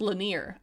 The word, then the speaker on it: Lanier: Or it's pronounced Lanier, I now know, because I've also now watched approximately 15 interviews with him on YouTube.